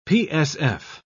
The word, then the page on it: psf: British English